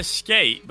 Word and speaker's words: SK8: I call it Skate, but I guess I'm pronouncing it wrong. It's SK8, the Infinity.